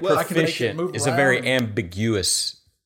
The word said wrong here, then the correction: ambiguous: Is that right term? – Ambiguous? – Ambiguous. That's the term I was looking for. – You put the wrong emphasis on the wrong syllable.